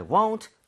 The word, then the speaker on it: won't: 그래서 여기서는 I won't, we won't. 오케이. 근데 중요한 거, won't 할 때 발음 아직까지도 입모양 풀면서 want로 했다. 그러면 원하다, W-A-N-T가 되기 때문에 이 모양 꼭 모으고 해주세요. Won't.